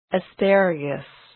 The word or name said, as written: asparagus